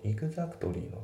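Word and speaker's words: exactly: で、あと発音なんだけど、イグザクトリーのところ、ね、イグザクトリーのその T が抜けてますね。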